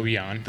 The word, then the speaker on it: Ian: And so my first trip here, I came in right when you guys opened, and I think Ian? – Oh, Eon. – Eon, is that it? – Yep. – Eon? – It's spelled Ian, and we give him a hard time about it, but it's Eon.